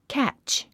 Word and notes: catch: kǽtʃ; キャッチ

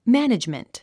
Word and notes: management: mǽnidʒmənt; マァネジメント

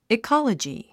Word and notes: ecology: ikάlədʒi; イカラジィ

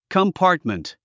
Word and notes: compartment: kəmpάːrtmənt; コンパートメント

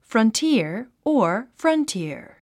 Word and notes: frontier: frʌntíə r; フロンティア

